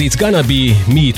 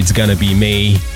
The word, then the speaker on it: It's Gonna Be Me: Az It's Gonna Be Me ugyanis a dal végén úgy énekli, hogy It's Gonna Be May, mint május angolul, úgyhogy áprilisban évről évre előkerülnek a mémek.